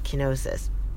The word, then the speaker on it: kenosis: And one of these ancient Greek words is called kenosis, and that is spelled K E N O S I S, kenosis.